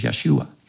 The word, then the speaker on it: Yeshua: Now, the interesting thing is the Hebrew word for salvation here, maybe you know this, but the name Jesus, the Hebrew pronunciation of Jesus is Yeshua.